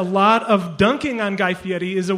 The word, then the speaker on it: Fieri: It's Guy Fieri. We check all our pronouncers.